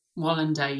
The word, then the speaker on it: Wallander: Wallander? – Wallander, yeah, young Wallander.